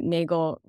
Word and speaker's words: Nagel: I guess it's a little funny that Nagel rhymes with Hegel if they're both concerned with being the bad Josh.